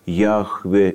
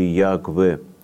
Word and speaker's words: Яхве: в современной библеистике более принято произносить это имя как Яхве или Ягве.